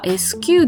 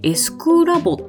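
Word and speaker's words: SQlab: でね、確かにアルファベットでは S と Q って書くんですけど、これどうやらですね、正しい読み方としてはドイツ語読みをするらしいんですね。で、正しい発音としては SQ ではなくて S クラボっていう読み方をするようでございました。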